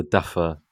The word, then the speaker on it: Duffer: Is it the Duffel Brothers? Have I said that right? – The Duffer. – Duffer, sorry. – Duffer? Yeah, it was Duffer. Yeah. You made me... – I thought it was Duffy. – It's Duffer. It's Duffer.